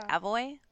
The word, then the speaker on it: McAvoy: James McAvery. – James That's who I was trying to think of. Yeah. – McAvoy? – It's not McAvery? – No.